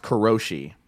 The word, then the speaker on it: karoshi: So, like, the word that I'm talking about is, I believe it's pronounced karoshi.